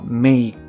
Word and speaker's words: make: で、ここであの、 live のですね、単語の語尾っていうのは E になるんですけれども、語尾の E というのはほとんど発音されないんですね。まあ、例えば take とか make、 cake。まあこれらなんかも同様で、後ろに E がくっついてるんですけれども、 E っていうのは発音しません。